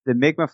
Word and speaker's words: Mi'kmaq: Yeah, good pronunciation for an American. I've heard some people butcher that. Yeah, the Mi'kmaq flag is almost the Mi'kmaq battle flag is almost identical to the Knights Templar flag.